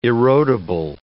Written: erodible

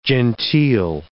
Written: genteel

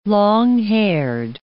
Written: long-haired

